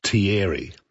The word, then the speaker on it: Thierry: This particular read says Thierry is pronounced Thierry.